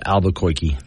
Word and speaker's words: Albuquerque: By the way, it's pronounced Albuquerque.